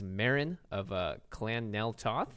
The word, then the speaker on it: Meren of Clan Nel Toth: And he's brought his Meren of Clan Nel Toth. Did I say that correctly? – Yeah. – No, you have to say it like this. Meren of Meren. Clan Nel Toth.